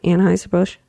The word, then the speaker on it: Anheuser-Busch: I said that right, right? Anheuser-Busch? – You said it right, yeah, this time, girl.